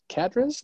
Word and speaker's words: Cadiz: Cadiz, yes. – Cadiz. – Cadiz. C-A-D-I-Z. – Okay. – Didn't pronounce properly.